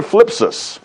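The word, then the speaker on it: thlipsis: It's the word phlipsis. It starts with TH, but you don't usually pronounce that.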